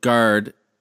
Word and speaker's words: Gard: And the Wisconsin Gard, he, he's, or how do you say his name? G A R D, right? – Gard, yes, yeah. Gard.